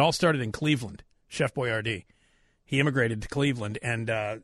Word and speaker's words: Boiardi: Because he actually spelled his name as B-O-I-A-R-D-I. That, and it's pronounced Boyardee. – Mm. – But they changed it to B-O-Y-A-R-D-E-E so that, like, kids could, like, pronounce it and recognize it.